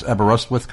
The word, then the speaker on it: Aberystwyth: Did I pronounce Aberystwyth correctly? – That is absolutely right.